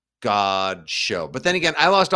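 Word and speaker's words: Godchaux: Is it God? Isn't it Godshow? – Oh, I don't know. Is that wrong? – I think it's Godshow, yeah.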